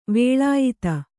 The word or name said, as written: ವಲಯಿತ